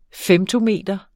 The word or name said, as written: femtometer